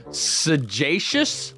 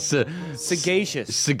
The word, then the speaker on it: sagacious: Sagacious? – Sagacious? Everyone point and laugh at your radio. – What is it? – Sagacious. It's sagacious. – Oh, I thought gay was G-A-Y. – According to this pronunciation... – Is G-E-Y okay? – G-E-Y.